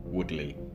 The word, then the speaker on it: Woodley: Well, actually, I should correct myself because that y'all pronounce it differently. So it's, it's Woodley.